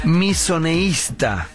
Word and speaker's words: misoneísta: ¿Qué es misoneísta? – Misoneísta. – Con acento en la segunda I.